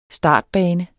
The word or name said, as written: startbane